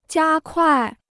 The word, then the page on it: 加快: jiā kuài